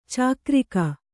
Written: ಚಕ್ರಿಕ

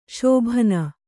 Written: ಶೋಭನ

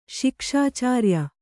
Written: ಶಿಕ್ಷಾಚಾರ್ಯ

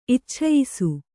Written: ಇಚ್ಛಯಿಸು

